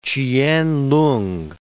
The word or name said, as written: Ch'ien-lung